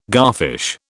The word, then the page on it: garfish: British English